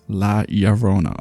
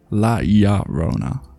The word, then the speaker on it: La Llorona: La Llorona. Okay. La Llorona.